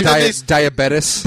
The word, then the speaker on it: diabetes: Diet fucking beaties. – Oh, what a shock. – Excuse me. It's diabetes. – Diabetes. – Pronounce it the right way, please.